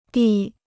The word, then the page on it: 第: dì